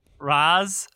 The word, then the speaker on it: Ra's al Ghul: Ra's.